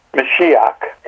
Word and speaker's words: Mashiach: How's that pronounced again? – Mashiach. – Mashiach. – Yes.